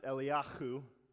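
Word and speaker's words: Eliyahu (אליהו): His name in Hebrew, it's pronounced Eliyahu. You got to say it with some phlegm.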